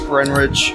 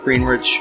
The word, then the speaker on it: Greenwich: Everybody knows the W is silent. You're from New England, for f***'s sake. You guys can't pronounce anything. – Worcester. – Yeah, Worcester. – Greenwich.